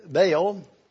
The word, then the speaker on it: Baal: Under the two preceding kings of Judah, Amon and Manasseh, the cult of other deities, especially Baal and Ashtaroth, had developed in the holy city. Most of us are familiar with Baal, or Baal as some pronounce it, and Ashtaroth.